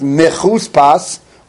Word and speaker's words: mechuspas: It's pronounced mechuspas with a vav, but in terms of ksiv, it's without a vav, and that's how you take the letters mem ches samach pei samach, and that's how you're going to get to 248.